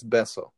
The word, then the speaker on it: Bezos: Bezos, yeah, and then like, there's a one guy who's like, if we're gonna use Bezos bucks, we might as well say the name correctly. It's Bezos.